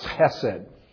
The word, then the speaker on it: chesed: It's pronounced chesed.